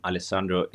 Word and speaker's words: Alessandro: Alessandro is pronounced perfectly, and the surname is Di Ruscio, but I don't expect you to achieve this level of Italian pronunciation.